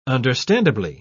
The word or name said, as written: understandably